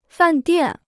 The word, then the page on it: 饭店: fàn diàn